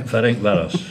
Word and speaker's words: Ferencváros: Ferencváros. – Yeah. – Ferencváros. – Frankie Valley. – I'm not even going to try. – Well, if you can say Ferenci, you can say Ferencváros.